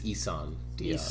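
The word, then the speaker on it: Isan Díaz: All right, last move in the NL West, the Diamondbacks trading Aaron Hill, Chase Anderson, and somebody help me with the pronunciation. Is it? – I believe it's Eason Diaz.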